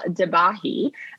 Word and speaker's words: Debahy: So we're here today with Paul Debahy.